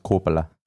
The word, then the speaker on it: Coppola: It's pronounced Coppola.